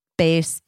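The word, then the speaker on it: Béis: Base is for you. Base, it's spelled B-E-I-S.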